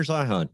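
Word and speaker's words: Zeihan: Peter Zion or Zian. I don't know how to pronounce his last name. – Peter Zion. Yeah.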